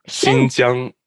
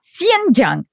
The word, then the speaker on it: Xinjiang: Xinjiang. – Okay. – Yeah. – Xinjiang. It's a soft S.